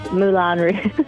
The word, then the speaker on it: Moulin Rouge: I don't even know if I said that right. – Moulin Rouge. – Okay, there it is, like in the song with Christina Aguilera, right? – Yeah.